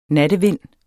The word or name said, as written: nattevind